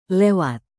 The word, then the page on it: lewat: indonesian